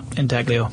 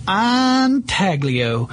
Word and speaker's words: intaglio: I thought that was intaglio. – No, intaglio. – Okay. – I listened. – The Italian G always fools me. – Yeah. Well, it may be in Italy it's pronounced that way, but darn it, here in the South we're calling it intaglio.